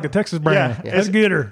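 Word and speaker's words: Goodr: So there's kind of this brand that I really like, and it's called Gooder. So not better, but Gooder. – Yeah, how do you spell that? – It's, I think it's G-O-O-D-R.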